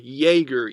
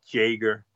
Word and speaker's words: Jaeger: Like in German, the J makes a Y sound. That's why he's Aaron Jaeger, even though it's spelled with a with a J.